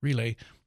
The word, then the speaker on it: Rile: And the head of the house, the owner, the husband, the father, the grandfather, Mr. Relay, again, it's spelled R-I-L-E, but it's not pronounced Riley's. He said it's not an Irish restaurant.